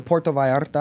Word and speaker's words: Puerto Vallarta: Actually, I went to Puerto Vallarta. I don't know if I'm saying that right. – Yeah. – But you said that perfect, actually.